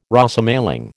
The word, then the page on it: Rosemaling: American English